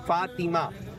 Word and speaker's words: فاطمہ: اس کا درست جو تلفظ ہے وہ ہے فاطمہ.